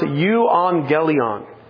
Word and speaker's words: euangelion: The gospel, the word gospel, comes from a Greek word that is pronounced euangelion. You can see the second letter. It's a U, but it's also a letter that is in English translated as a V.